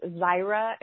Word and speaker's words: Zhayra: Yes, Zyra, and it's spelled Z-H-A-Y-R-A, and then Escobar.